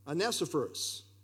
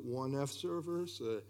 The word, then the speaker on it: Onesiphorus: Just remember the first two letters, on. Because always, how do you pronounce this? Is it one F servers? How do you pronounce this? It's on. Onesiphorus. Anyway, you want to try it, Jeff? – Yeah.